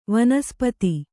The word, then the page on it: ವನಸ್ಪತಿ: vanaspati